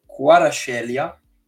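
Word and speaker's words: Kvaratskhelia: Il nuovo acquisto del Napoli, che mi sono insegnato come si pronuncia il nome, perché eh, quando ho visto il nome ho detto Dio, invece si, si pronuncia Quarascelia, okay.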